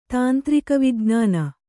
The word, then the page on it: ತಾಂತ್ರಿಕವಿಜ್ಞಾನ: tāntrika vijn`āna